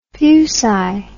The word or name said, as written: fuci